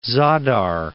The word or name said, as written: Zadar